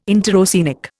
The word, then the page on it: interoceanic: Indian